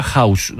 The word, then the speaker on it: house: Humildemente se pronuncia House. No sé de qué hará. – House. – J-A-U-S, no sé de qué hablabas.